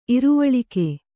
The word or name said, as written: ಇರುವಳಿಕೆ